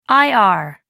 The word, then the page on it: ir: American English